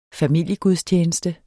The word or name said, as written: familiegudstjeneste